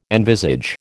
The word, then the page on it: envisage: American English